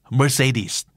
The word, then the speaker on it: Mercedes: Mercedes